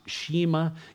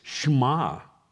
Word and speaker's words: Shema: I know it looks like it's pronounced Shema. It's not. There's not even an E in the Hebrew word. It's Shema.